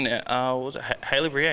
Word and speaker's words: Haileybury: You in Haileybury, eh? – Yeah, Haileybury College. Am I saying it correctly? – Yes, you are.